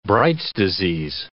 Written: Bright's disease

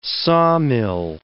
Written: sawmill

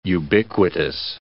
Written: ubiquitous

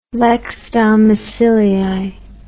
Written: lex domicilii

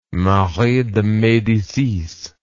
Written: Marie de Medicis

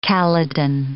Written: kallidin

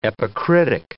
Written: epicritic

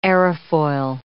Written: aerofoil

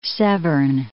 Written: Severn River

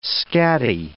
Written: scatty

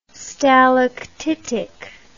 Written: stalactitic

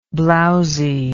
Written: blowziness

